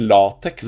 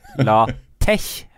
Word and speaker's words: LaTeX: Og Julian Ravn Trapp-Meyer spør på YouTube om den støtter latex, latex og det gjør den ikke. – Ja, det heter faktisk latex har jeg fått høre for folk. – Latex. – Ja, det uttales visst sånn.